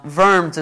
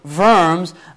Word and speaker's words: Worms: Now, Worms is spelt with a W, but in German, W is a V, okay? Volkswagen. Anyways, Diet also, by the way, doesn't mean diet as in, you know, you need to have a more crunchy diet or whatever.